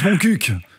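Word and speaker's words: Montcuq: Ça se prononce Moncuq, en fait.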